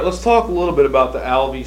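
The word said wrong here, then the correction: Alves: Street School. I think I'm pronouncing that right. – Alvis. – Alvis, mm-hmm. Okay. That's A-L-V-E-S.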